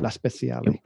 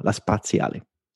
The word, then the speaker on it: La Spaziale: La Speciale. – No, La Spaziale. – Thank God. – It's, you know, it's, I'm it's spatial, it's space, not special.